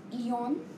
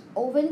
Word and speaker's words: Eoin: Even though it is spelled as Eon, it's Owen Colfer Okay, so the book.